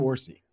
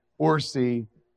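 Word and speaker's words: Orci: They just hired Robert, how do you say his name? – Orsi. – Orsi, to write a Spider-Man universe spinoff.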